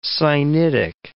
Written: Sinitic

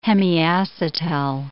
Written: hemiacetal

